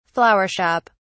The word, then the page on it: flower shop: British English